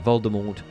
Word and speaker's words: Voldemort: Being that it is an invented French word, evidently the T at the end of Voldemort should be silent.